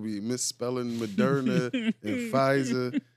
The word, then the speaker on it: Pfizer: If you don't put that P in front of the Pfizer, your ass is going to be done. Niggas going to spell it F-I-Z-A-R. Pfizer.